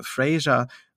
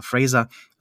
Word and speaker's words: Fraser: Das gleiche gilt natürlich auch für, für äh, Brandon äh, Fraser oder Fraser, so möchte er ja ausgesprochen werden, Entschuldigung, lieber Brandon, du hast genug gelitten.